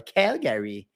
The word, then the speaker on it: Calgary: Calgary, Calgary, como é que fala? Calgary.